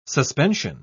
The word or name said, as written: suspension